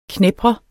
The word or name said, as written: knebre